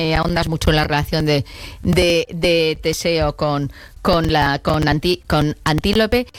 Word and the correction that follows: Antíope: pero lo he dicho mal el nombre, es Antíope.